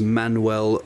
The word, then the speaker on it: Manwel: His name is Manuel T. And Manuel is spelt M-A-N-W-E-L.